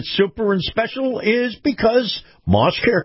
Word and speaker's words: Maus: Now, this portion of the program is brought to you by our friends at Moss. It's spelled M-A-U-S, Moss Acura.